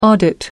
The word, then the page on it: audit: American English